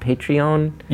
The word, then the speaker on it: Patreon: That's why something like I'm so fascinated by is it pronounced Patreon? – Yeah, yeah.